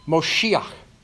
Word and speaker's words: Moshiach: The word here in Hebrew for save is Moshiach. It's nearly the pronunciation and spelling of Messiah, Messiah.